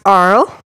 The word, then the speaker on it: Arles: The town is pronounced Arles.